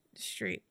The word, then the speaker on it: Streep: Am I saying her name right? I don't know. Stripe. Anyways, Streep. Meryl Streep.